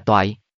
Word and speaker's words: Toại: Chữ này đọc là gì? Trịnh Phàm hỏi A Minh ở bên cạnh. Đọc là Toại.